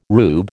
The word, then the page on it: rube: American English